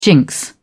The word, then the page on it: jinx: British English